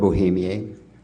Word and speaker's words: Bohemier: Gerald Bohemier, G-E-R-A-L-D, Bohemier spelled B-O-H-E-M-I-E-R. In French, it's Bohemier, but we'll go along with the Bohemier or Bohemier.